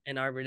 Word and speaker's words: Ann Arbor: Ann Arbor? – Oh, Ann Arbor. – Ann Arbor, there you go.